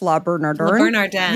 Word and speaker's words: Le Bernardin: Le Bernardin.